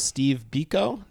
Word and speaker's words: Steve Biko: And first track we'll talk about is Steve Biko.